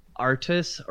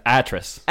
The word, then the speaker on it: Atrus: Artus or Atrus? – It was Atrus.